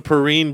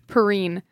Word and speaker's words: Perrine: I do know it's called and pronounced the Perrine Bridge. – Perrine.